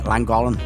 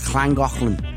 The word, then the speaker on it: Llangollen: Or us Scousers call it Llangollen, but it's not. It's Llangollen. What a beautiful country it was.